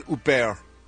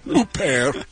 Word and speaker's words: Huppert: Lead actress nominees, Isabelle Huppert. You gotta say it like that, like you're choking almost on something, like you got something in your windpipe. Huppert for Elle,